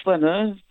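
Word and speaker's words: flâneur: Who was the Flaneur? And also, am I pronouncing that correctly? – Yeah, that's correct. Yeah, the Flaneur.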